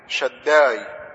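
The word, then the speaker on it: شداي (שדי): نطقها بالعبري شداي. شداي دي نفسي تحفظ اللفظ ده، انا الله شداي، يهوى شداي.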